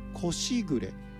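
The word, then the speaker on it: 小時雨: お蚕さんが桑の葉っぱをむしゃむしゃと一斉に食べるときに生じるさあパラパラと小雨が降っているように聞こえる心地よい音のことをこしぐれ、これ蚕のしぐれと書いて時の雨と書いてこしぐれというそうです。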